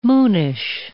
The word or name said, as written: moonish